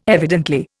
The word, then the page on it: evidently: Indian